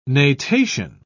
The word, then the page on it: natation: British English